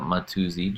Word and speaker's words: Mattiuzzi: Yeah, I don't really have much of a universe on the internet, except for my Instagram account, which is my name spelt kind of phonetically, which is John M-A-2-Z, which spells out Mattiuzzi, John Mattiuzzi.